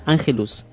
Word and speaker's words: Ángelus: Ángelus. – Sí. – Ángelus, ¿cómo Angelus es? Ángelus. El Ángelus.